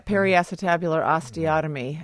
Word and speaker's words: periacetabular osteotomy: Parasitabular osteotomy, is that correct pronunciation? – Well, yeah, periacetabular osteotomy.